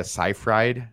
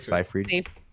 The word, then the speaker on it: Seyfried: Next up, we have the Kevin Bacon Amanda Seyfried. How do you say her last name? – Seyfried. – Seyfried. – Yeah. – Seyfried. Classic.